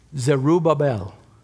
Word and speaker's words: Zerubbabel: Zerubbabel. Not Zerubbabel, not Zerubbabel, Zerubbabel.